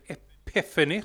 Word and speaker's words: Epiphany: Webb, eller FNE, eller Epiphany som det ska uttalas.